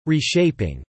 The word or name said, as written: reshaping